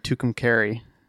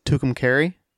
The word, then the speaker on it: Tucumcari: And it's in Tucumcari. – It's in what? – Tucumcari, New Mexico. – Oh, okay. Cool. – I looked up how to say it, and that's what it said.